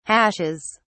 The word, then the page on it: hashes: British English